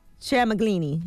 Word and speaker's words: Tramaglini: Tramaglini. – Thomas Tramaglini. – Is it Chamblee? – Tramaglini. – Thomas Trampolini.